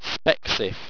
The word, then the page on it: spexif: owstwecian